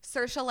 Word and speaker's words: Saoirse: Saoirse like inertia.